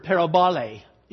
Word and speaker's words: parabole: But a short answer is that the word parable in Greek never, and that is the Greek word, parabole, is the Greek pronunciation of it, is not in the Gospel of John.